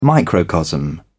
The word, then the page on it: microcosm: British English